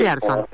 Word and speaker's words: Pearson: Pearson. Ahí, bueno, porque hay veces suena una u otra vocal y la cosa cambia.